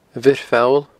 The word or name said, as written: abhiorpholl